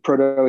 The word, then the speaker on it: Proteau: It's proto is spelled, it's like prototype is how you say it, but it's PROTEAU.